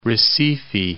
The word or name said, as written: Recife